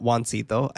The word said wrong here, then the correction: Jacinto: I keep calling him Juancito. – Yeah, I noticed. – But it's not Juancito. I can't pronounce his name correctly. So please, both of you, please correct me. – Jacinto. – Jacinto? – Yeah. – Jacinto. Thank you.